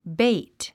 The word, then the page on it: bait: béit; ベイト